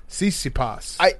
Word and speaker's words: Tsitsipas: Tsitsipas. Yeah. – Tsitsipas.